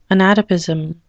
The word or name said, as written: anatopism